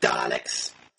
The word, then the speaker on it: Daleks: But Daleks, pronounced with accent on both syllables, are a race of physically challenged life forms from the BBC One Physics and Cosmology documentary Doctor Who.